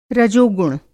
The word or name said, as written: रजोगुण